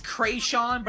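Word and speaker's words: Kreayshawn: Well, it's Krayshawn, but it's supposed to be a play on creation.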